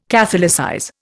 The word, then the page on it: catholicize: Indian